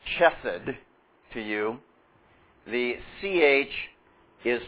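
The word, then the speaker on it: chesed (חסד): First of all, you will hear it maybe spoken as chesed, and that's not really how we want to pronounce it, all right? This is chesed.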